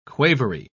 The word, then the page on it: quavery: British English